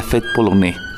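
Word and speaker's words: Fête polonaise: la Fête Polonais sería la pronunciación.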